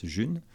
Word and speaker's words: G1: Alors, ça s'écrit G1, ça se prononce June.